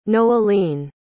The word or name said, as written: noeline